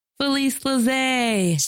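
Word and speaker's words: Felice Lazae: You can reach out to Felice at Felice Laze.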